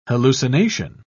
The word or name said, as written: hallucination